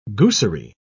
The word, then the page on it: goosery: British English